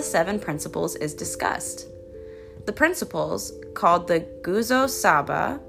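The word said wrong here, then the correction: Nguzo Saba: Nguzo? Hold on, I did look up the pronunciation. It's pronounced Naguzo Saba. Naguzo Saba.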